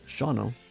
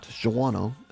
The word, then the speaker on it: Shawano: If you're not sure where it's at, and by the way, it's pronounced Shano, not Shawano, as so many people say.